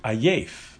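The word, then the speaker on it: Ayef (עייף): Ya'ef, how do you say tired? Ayef. Ayef, ayin, first ayin, then yud, and fei sofis.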